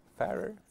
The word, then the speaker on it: Farer: Hur uttal uttalar man det där? Farer. Farer. – Farer. – Någon, som, Farer. någon som färdas.